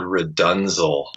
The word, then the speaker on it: RDNZL: And, he had me learn for the first one this Frank Zappa tune called Redunzel. I don't know if you're familiar with it. – I don't know that one. – It's like, I think it's RDNZL. That's how it's spelled.